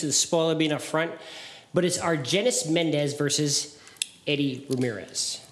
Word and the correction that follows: Argenis: Arjenis. Okay. I need you to get the country right. I need you to get the name right.